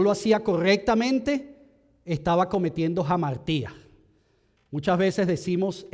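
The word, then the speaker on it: jamartía (ἁμαρτία): La palabra de Dios, cuando fue escrita en el original en griego, usa la palabra amartía, jamartía se pronuncia.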